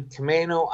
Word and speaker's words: Camano: Hey, Mike, I live on Camano Island in Washington State now. – How do you spell that? – C-A-M-A-N-O.